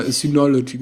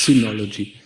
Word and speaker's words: Synology: Die haben nämlich immer Synology gesagt, Synology wie Scientology. Aber es heißt Synology. Wie Syntax.